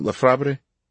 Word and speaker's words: Lefebvre: Lefebvre, as in, pronounce it L-E-F-E-B minus the F-R-V-E part. – Instead of the B, I'm more pronounced the B. – Lefebvre.